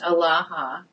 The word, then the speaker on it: Alaha: The basic word for God is El, and in Aramaic it's pronounced Alaha with an addition of the letter He.